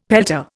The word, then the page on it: pelta: Indian